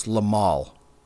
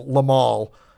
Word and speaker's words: Limahl: I, am pretty sure his name is pronounced Limahl, not Lionel.